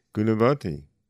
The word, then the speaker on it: Gunavati: Also, we want to give Ms. Rodriguez a new name, Gunavati. This is a very beautiful name. I'm going to spell it for you. G-U-N-A-V-A-T-I. Gunavati.